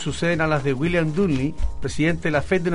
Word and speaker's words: Dudley: Dudley. – Es lógico. – Todos los terminados en L-E-I es Foxley. Dudley. – Y la U se pronuncia como A.